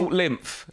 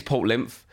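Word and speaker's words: Port Lympne: Port Lymph, is that how we pronounce it? – Port Lymph. – Yeah. – Yes, I think it is Port Lymph.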